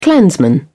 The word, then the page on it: clansman: British English